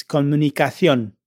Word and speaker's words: comunicación: This time, the ending is a C instead of a T, and the pronunciation is slightly different. It's comunicación.